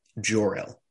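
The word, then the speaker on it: Jor-El: And I was kind of I thought it was cool that you know um, Jarell. Am I saying that right? – Jorel.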